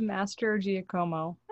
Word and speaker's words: Mastrogiacomo: Is it Mastrogia? How do you say your last name? – It's Mastrogiacomo.